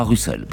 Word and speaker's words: Russell: Le tribunal Russell, on prononcera Russell par commodité, entend ainsi faire œuvre d'information et d'éducation, comptant sur le retentissement médiatique de ce procès, qui conclura à la culpabilité des États-Unis.